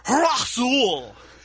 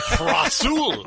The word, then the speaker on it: Rasul: And it's R-A-Z-A-U-L, and it's pronounced Rasul. Rasul. – Rasul. That's how it's pronounced.